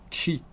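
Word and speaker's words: ĉi: Pour préciser une proximité immédiate, on peut ajouter tie chi ou chi tie. Chi, ça s'écrit c chapeau i.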